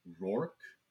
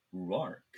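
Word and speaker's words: Rhuarc: We have Ruark, or, according to the pronunciation in the back of the book, Rourke. But I've heard Ruark, so we are going to go Ruark. So he's just like, oh, holy shit, they're black-veiled Aiel.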